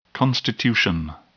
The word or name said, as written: constitution